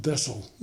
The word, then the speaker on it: Wesel: Wessel, W-E-S-E-L. – Okay. – Pronounced Bessel, Wessel.